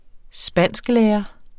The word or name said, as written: spansklærer